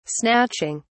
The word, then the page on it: snatching: British English